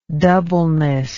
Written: doubleness

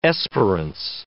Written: esperance

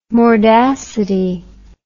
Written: mordacity

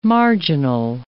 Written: marginal

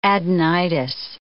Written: adenitis

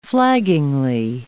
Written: flaggingly

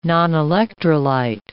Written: nonelectrolyte

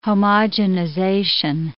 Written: homogenization

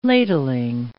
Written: ladling

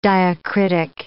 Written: diacritic

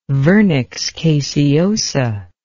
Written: vernix caseosa

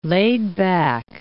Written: laid-back

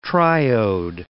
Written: triode

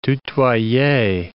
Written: tutoyer

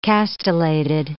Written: castellated

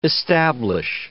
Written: establish

